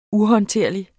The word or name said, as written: uhåndterlig